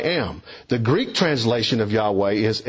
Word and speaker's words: Yahweh: For this is a Greek rendering of the Hebrew covenantal name of God, the Tetragrammaton, which is Yahweh, YHWH, pronounced Yahweh, first revealed to the Hebrew people by Moses as it was revealed to him at the burning bush.